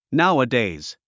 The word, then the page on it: nowadays: náuədèiz; ナゥアデイズ